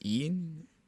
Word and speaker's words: ein: And let me see, in this piece, I don't know how you pronounce it, E-I-N-E, is it pronounced Ein? – Ein.